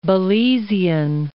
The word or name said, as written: Belizean